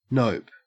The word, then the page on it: nope: Australian English